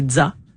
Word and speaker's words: ZZA: Un groupe de jazz de Québec qui s'écrit ZZA. Donc, c'est Dza. Comme la fin de pizza.